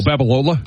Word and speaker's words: Babalola: Did the other kid play the, I don't want to pronounce his name, Balboa, whatever his name is? – Oh, Babalola? – Babalola. I was close.